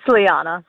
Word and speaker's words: Liana: It's Liana. Sorry.